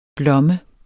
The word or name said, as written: blomme